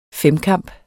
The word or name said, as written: femkamp